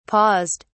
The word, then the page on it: paused: British English